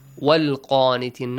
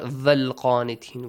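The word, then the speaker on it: والقانتين: Sometimes wow is also important. Wal qanitin, not val qanitin. We don't have va.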